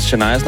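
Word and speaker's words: Chania: Jeg troede, hun havde udtalt vores producerassistent Shanias navn forkert. Det var bare det sted. Der er simpelthen kommet for mange beskeder til, at jeg ikke lige skal sige, det er udtalt som bare Shania.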